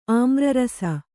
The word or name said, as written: ಆಮ್ರರಸ